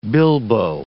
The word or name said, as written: bilbo